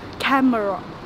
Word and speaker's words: camera: Camera. – No, los nativos lo pronuncian con dos sílabas. Camera, camera, camera.